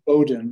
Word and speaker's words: Bowdoin: Bowdoin. Yeah, the W is silent.